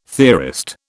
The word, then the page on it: theorist: British English